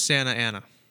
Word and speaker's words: Santa Ana: Also, it is Santa Ana. – Santa Ana? – Yes. – Okay, I'm sorry for pronouncing that wrong.